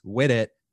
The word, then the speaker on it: Whittet: But welcome, James Whittet, to the podcast.